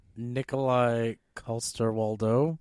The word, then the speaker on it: Nikolai Coster-Waldau: Nikolai Coster-Waldau.